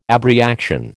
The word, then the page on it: abreaction: American English